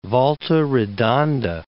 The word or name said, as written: Volta Redonda